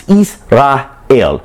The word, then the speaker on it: Israel: If you ever think about the name of the word Israel, it's actually three syllables. Israel, under the Kemet culture or the Egyptian culture, it would be understood Is, short for Isis or moon.